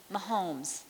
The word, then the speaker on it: Mahomes: Am I saying it right? Mahomes with an M? Mahomes, thank you, Mahomes.